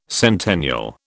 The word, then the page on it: centennial: British English